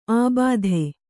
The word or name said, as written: ಆಬಾಧೆ